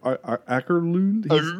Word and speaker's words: Åkerlund: Åkerlund. How do you pronounce the A with the little circle over top? – You got it right. – Åkerlund?